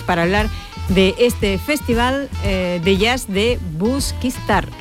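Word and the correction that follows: Busquístar: Busquistar. – Busquistar. El acento. Me ha faltado el acento. Busquistar.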